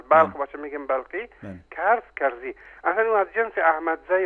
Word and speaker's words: کرزی: مثلا ما بسیار اتفاق افتاده که در ایران برای ما هیچ خوشایند نبوده که رئیس جمهور مملکت رو کرزای تلفظ میکنه در اخبار مثلا کرزای، در حالی که یادم کرزای هست یعنی این شخص و نه تنها کرزای بلکه حتی در واقع درستش کرزی هست.